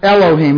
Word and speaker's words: Elohim: The first one is Elohim. Pronounced Elohim. Looks like Elohim, but it's pronounced Elohim.